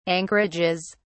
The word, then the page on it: anchorages: British English